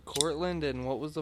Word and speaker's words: Cortlandt: Woodlawn Cemetery in Van Cortlandt Park, or Cortland. It's spelled like Cortland, but with a T at the end, too.